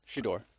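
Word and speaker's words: Shedeur: Shador.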